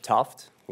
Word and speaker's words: Thooft: My name is Attorney Derek Tuft. Last name is spelled T-H-O-O-F-T.